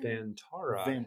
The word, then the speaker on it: Vantara: We have Hitachi Vantara. Am I saying that right? – Vantara. Hitachi Vantara. – I always get those vowels wrong.